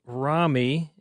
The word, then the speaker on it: Rami: So it's Rami, as in Rambo.